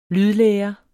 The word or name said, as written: lydlære